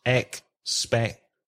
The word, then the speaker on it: expectancy: Just one small thing, Javier, that when you say the word expectancy, you misplace the stress on expectancy. It has 4 syllables, expectancy. And you need to place the stress on the second syllable. So expectancy.